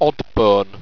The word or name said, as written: oddbone